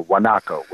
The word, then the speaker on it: guanaco: They are the native version of that, the wild version in Patagonia, is called a guanaco with a G. It's pronounced with a W, or guanaco, some people say up here.